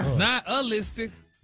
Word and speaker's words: nihilistic: But you actually pronounced it correctly the last time you said really? – It. – Really? Nihilistic? – Wow. – Stop putting the ooh in there.